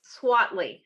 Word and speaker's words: Swiatly: Swatley.